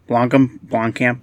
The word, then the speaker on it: Blomkamp: I think it's Blomkamp.